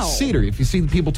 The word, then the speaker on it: Seder: It's spelled S-E-D-E-R. – I would say cedar. – Looks like cedar, right? It's a noun. It's pronounced seder.